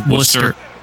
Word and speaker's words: Worcester: Excuse me, that's Worcester. – Sorry, Worcester.